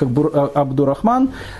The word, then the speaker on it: Абдурахман: потому что абдул Аль-Рахман произносить очень тяжело, поэтому в России обычно это имя произносится как Абдурахман,